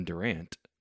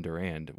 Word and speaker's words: Durand: Cast: Ryan Reynolds, Scott Speedman, Rosario Dawson, I believe you pronounce this Mariel Enos, Kevin Durand, which not Kevin Durant, but Kevin Durand, which is that guy.